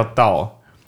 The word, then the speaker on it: DAO: 然后我们现在在讲 DAO DAO，它合起来叫道，就是道教那个道那个发音。